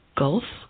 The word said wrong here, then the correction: Guelph: Well, my best attempt. – Well, was that okay? What is it? Was that okay? – Well, yeah, Guelph is the correct pronunciation.